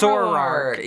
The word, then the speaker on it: Zoroark: Zoroark? I don't know if I'm saying that correctly. – Zoroark.